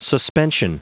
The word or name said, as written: suspension